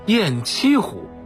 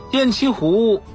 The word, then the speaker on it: 雁栖湖: 听众朋友，今天给您介绍的这个水系啊，叫做雁栖湖，这是咱北京人都熟悉的一座湖，咱老百姓叫白了都给叫成了雁栖湖了。实际上啊，这个地方啊应该读作雁栖湖。雁栖湖位于怀柔城北 8公里，每年的春秋两季常常有成群的大雁来湖中栖息，故而得名雁栖湖。